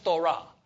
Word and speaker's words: phthora: It's phonetically is spelled P-H-T-H-O-R-A. Now, the P-H is silent, so it's pronounced thora, okay?